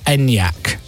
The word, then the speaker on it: Enyaq: Now, if you've never seen the word Skoda, Skoda Enyaq written down, have a guess at how you'd spell Enyaq or how you pronounce it. And I'm going to tell you, how will you pronounce this word? E-N-Y-A-Q. Enyaq. – Yeah. – That's exactly how you say it.